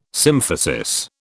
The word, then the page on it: symphysis: British English